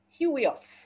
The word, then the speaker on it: huios: That word, the Greek word is hu, huios, H-U-I-O-S.